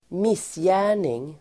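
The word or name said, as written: missgärning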